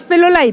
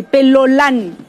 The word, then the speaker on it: pelolán: Tenemos también, de pelón pasamos al pelolán, no es pelolay, pelolán.